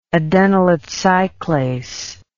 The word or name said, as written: adenylate cyclase